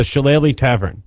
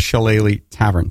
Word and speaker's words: Shillelagh Tavern: It's the Shillelagh Tavern. – The Shillelagh Tavern.